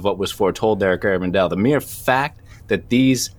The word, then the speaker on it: Garabandal: So the apparitions happened in Garabandal, Spain, from 1961 to 1965, despite how Mark pronounced it three times just now.